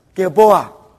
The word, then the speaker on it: Gilboa: Gilboa.